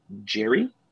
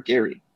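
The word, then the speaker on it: Gerry: The Niners, they waive Nathan Jerry or Gary. Depends how you want to pronounce it. – It's Jerry. – Some people say Gary.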